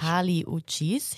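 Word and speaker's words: Kali Uchis: Carly Uchis.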